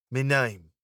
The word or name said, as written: me name